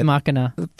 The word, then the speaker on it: machina: So I know an absurd amount of stuff about it because I just had to research Ex machina. – Why is it pronounced that way, though? Fourth is because it's Latin. It's a Latin phrase,